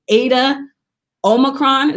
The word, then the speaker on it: Eta Omicron: And in 2012, Jasmine became a member of Zeta Phi Beta Sorority Incorporated through the electrifying Eta Omicron.